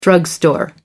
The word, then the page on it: drugstore: American English